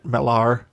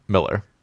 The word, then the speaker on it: Millar: We should just start pronouncing it Millar. – Well, it's spelled with an A, but it's pronounced Miller.